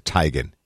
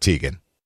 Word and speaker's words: Teigen: Oh, by the way, and she does pronounce it Teigen, not Teigen.